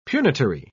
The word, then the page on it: punitory: British English